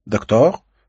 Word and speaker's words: Доктор: — Доктор? – — спросила она. Ударение на последнем слоге, по-французски.